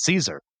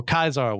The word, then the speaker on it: Caesar: He can't imagine a world where he doesn't get revenge against Caesar or Caesar, which is the actual correct Latin pronunciation, but I'm going to flip-flop between the two.